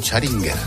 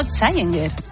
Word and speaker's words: Challenger: Ha dicho Charinger. – Que no, Challenger.